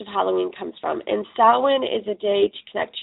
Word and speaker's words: Samhain: It is, the way it is spelt, it looks like Samhain. And I've heard people pronounce it this way before, but the correct pronunciation is actually Samhain.